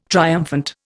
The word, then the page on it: triumphant: Indian